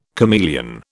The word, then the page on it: chameleon: British English